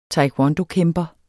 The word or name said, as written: taekwondokæmper